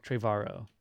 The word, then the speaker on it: Trevorrow: Trevorrow.